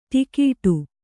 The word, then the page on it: ಟಿಕೆಟ್ಟು: Kannada